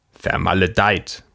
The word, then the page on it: blasted: /ˈblɑːst.ɪd/; /ˈblæst.ɪd/